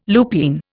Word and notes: lupine: Indian

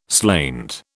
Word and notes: slainte: British English

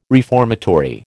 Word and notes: reformatory: American English

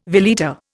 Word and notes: veleta: Indian

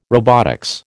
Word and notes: robotics: American English

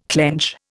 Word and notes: clench: Indian

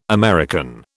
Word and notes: American: British English

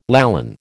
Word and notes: Lallan: American English

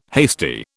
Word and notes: hasty: British English

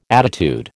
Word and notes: attitude: American English

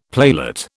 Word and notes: playlet: British English

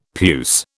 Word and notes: puce: British English

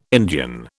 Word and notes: Indian: British English

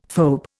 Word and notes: phoebe: Indian

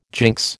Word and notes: jinx: American English

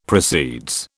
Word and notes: proceeds: British English

